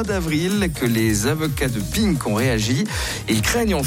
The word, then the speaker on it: PINC: Alors ça se prononce Pink phonétiquement, mais ça s'écrit P.I.N.C.